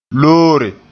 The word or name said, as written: loore